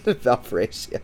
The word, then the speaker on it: Valparaiso: New Mexico State versus, I don't even know how to pronounce this, Valparaiso? – Yeah, what? Give me New Mexico State. I don't even know who that is. – Valparaiso.